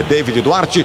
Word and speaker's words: David Duarte: O David Duarte, é o David, né? A pronúncia desse nome dele é David Duarte.